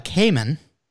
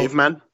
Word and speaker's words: caiman: We've got the caiman. It's spelled caveman. – No, caiman. It's spelled C-A-I-M-A-N.